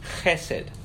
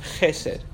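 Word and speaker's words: chesed (חֶסֶד): In the Hebrew, it is pronounced chesed, chesed.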